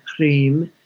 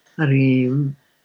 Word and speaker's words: Hrim: She's a mantra that, you know, a seed mantra, which is associated with her, which is the mantra Hrim. It's spelled H-R-E-E-M. I'm spelling it phonetically, Hrim.